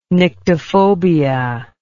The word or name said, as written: nyctophobia